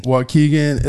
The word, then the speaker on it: Waukegan: So tell me, how do you say this? Waukegan, Illinois? – Waukegan, Illinois. – Waukegan, you heard that.